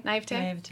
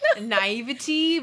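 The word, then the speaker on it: naïveté: Like naivety because that's how it would be pronounced. But it's had an accent. Had an accent aigu.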